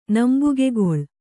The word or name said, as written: ನಂಬುಗೆಗೇಳು